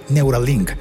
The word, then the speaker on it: Neuralink: También le pertenece Neural Inc. No, perdón, lo dije mal. Neuralink.